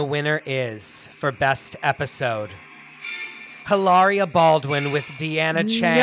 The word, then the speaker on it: Hilaria: Hilaria Baldwin with Deanna Ching. – She would cut her throat if she heard her name being pronounced like that. – It is Hilaria.